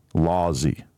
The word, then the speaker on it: Lawsy: Lawsy.